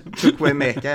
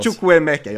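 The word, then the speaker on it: Chukwuemeka: Du menar Chukwue Meka ja, som vi fick in från Aston Villa. Ja. Där, där satt uttalet. Kör, kör en gång till så vi lär oss. Chukwue Meka, jag har övat på den hela hösten.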